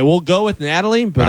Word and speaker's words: Nathalie: According to court documents in the Miami-Dade County, how would you say it? N-A-T-H-A-L-I-E. – Natalie. – That's Natalie? – Yep. – With an H in there? – Yep, it's silent.